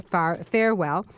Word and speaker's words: Farwell: I say farewell because I believe that it's an apropos thing for someone dealing in money if you can farewell. But I will say it so you can spell it properly. Farwell, F-A-R-W-E-L-L.